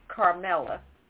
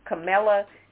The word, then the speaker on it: Kamala: Tucker Carlson in general, but more specifically this week, I was reading that Tucker Carlson deliberately calls the vice president Carmella. I think that's it. Kamala, something. He deliberately mispronounces her name all the time.